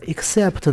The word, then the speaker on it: except: 另外，speci 这个词根还有一种变形啊，就是 except，e x c e p t，它就相当于 ex，这个 ex 是 out 的意思，后边再加上 spect，except，而这个 except 呢？它的 s p e c t 这个词根的 s 又不发音了，归入到前面的 e x，ex，ex，ex x 归入到前面了，所以就合起来变成了 e x c e p t，except。